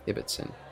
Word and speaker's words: Ibbotson: Ibbotson.